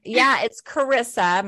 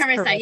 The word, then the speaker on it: Charissa: Well, it's just, you know, it sounds like charisma basically, but it's Carissa and the H is silent.